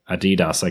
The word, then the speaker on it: Adidas: Adi Dassler is his name, so Adidas, I guess, is the right way to say it.